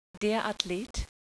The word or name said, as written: Athlet